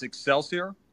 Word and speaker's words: Excelsior: It's Excelsior Brazilian Jiu-Jitsu.